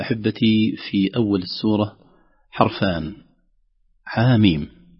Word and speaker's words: حم: في العادة لو لم نسمع كيفية تلاوتها قد يقرأها الإنسان حم حم حم أي شيء، لأن احنا في العادة لا ننطق الحرف بلقبه أو بصورة، لكن ننطق الحرف كحرف مبنى بفتحة ضمة كسرة كذا. لكن هذا يدل على أن القرآن متلقى، أننا ما قرأناها حم أو حم أو كذا، قرأناه حاميم، لم نقرأه حاء ميم أيضا، رغم أن نقول ألف باء تاء ثاء في نطق الحروف الهجاء نضع الهمزة.